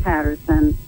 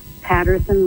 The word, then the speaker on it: Patterson: Definitely, if a person was to put my name, Darilyn Patterson, I better spell it right, into a Google search, will say D-A-R-I-L-L-Y-N as in Nancy, Patterson with two T's, P-A-T-T-E-R-S-O-N.